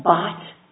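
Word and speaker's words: baht: 3.009 is the bot price. I may be saying it wrong. My law clerk says rhymes with mot, but that's the Thai dollar.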